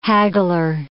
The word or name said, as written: haggler